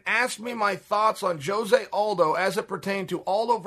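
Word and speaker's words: José Aldo: He said, Jose Aldo.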